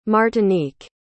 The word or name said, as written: martinique